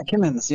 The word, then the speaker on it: Kimmons: Yeah, Kimmons, yep.